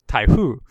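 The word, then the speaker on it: taifu (台風): And it turns out that that in Japanese is pronounced taifu, which is Japanese for typhoon.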